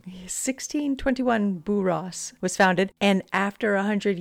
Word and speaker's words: Borås: Borås. – Borås. I looked it up. – Uh-huh. – It's spelled B-O-R-A-S. – Uh-huh. – And above the A, there's a circle. – Ooh, Borås. – Borås. – Borås.